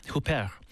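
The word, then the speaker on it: Huppert: Very famous actress, received the Global Icon Award, and legendary French actress Isabelle, how do I say this, Hooper? – Hooper was honored with a Lifetime Achievement Award.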